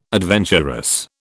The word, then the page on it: adventurous: British English